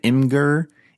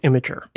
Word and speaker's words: Imgur: Imgur. – They say it's pronounced Imgur. – Imgur. – I always pronounced it Imgur.